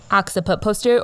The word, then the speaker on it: occiput posterior: And also, 2 points about the oxo posterior. Is that how you say it? – Occiput posterior, or we just call it OP.